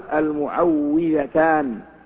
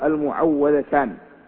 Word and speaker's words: المعوذتان: ولعلنا لم ننسى ما ذكرناه بالأمس أنه يقال المعوذتان ولا يقال المعوذتان بل ما يجري على ألسنة العامة المعوذتان أو المعوذتين فإنه غير صحيح في النطق لأنهما يتعوذ بهما ولا يعوذان.